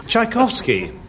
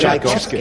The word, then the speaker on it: Tchaikovsky: Well, there's a T there on the front of Tchaikovsky. – It's pronounced Ch.